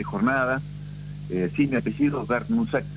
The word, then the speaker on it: Brnusak: este, entonces, pero se pronuncia Bernusac, es así. Lo podemos castellanizar así entonces.